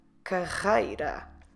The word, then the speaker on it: carreira: So for that, to move up the career ladder, we say subir na carreira. Career, carreira. Como se escreve carreira? C-A-R-R-E-I-R-A. Carreira. The double R, we do the R sound.